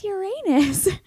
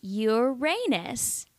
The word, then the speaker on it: Uranus: Anyway, she says, many people like to joke about the name of this planet, but it's actually pronounced Uranus. So she clarified that it's not Uranus. You have to say Uranus.